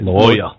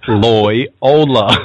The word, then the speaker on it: Loyola: Loyola. – How do you say it? – Loyola.